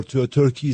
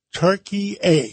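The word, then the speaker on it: Türkiye: Give us the, I used to call it Turkey. Now you call it? – Turkey. – Turkey A.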